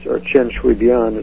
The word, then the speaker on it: Chen Shui-bian: Chen Shui-bian is how you really pronounce it, is being pressed to resign 2 days after Chen announced he would relinquish some of his own power to his cabinet.